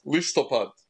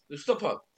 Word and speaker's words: Listopad: Listopad. – Listopad. – That's right. So it's Listopad.